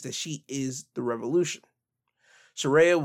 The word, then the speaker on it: Saraya: After this, we will have Saraya. Yes, that's the way to pronounce Paige's actual name, Saraya.